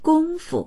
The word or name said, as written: gong1fu